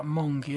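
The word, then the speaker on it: Mynci: La última palabra se escribe Minzy, pero se pronuncia Monkey. Ellos dijeron que era una forma de deletrear Monkey usando los sonidos de las letras en galés.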